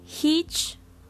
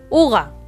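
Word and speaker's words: hygge: O, como dice aquí en el artículo, que yo no lo sabía porque yo lo pronunciaba así, se pronuncia Uga. no me preguntéis por qué, pero se pronuncia Uga y se escribe así.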